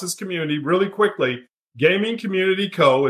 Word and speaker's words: CXMMUNITY: The reason why it's spelled CXMMUNITY is that the X is the only character that is represented on your controller as well as your keyboard. – Oh. – So Community, it's pronounced Community.